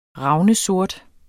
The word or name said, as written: ravnsort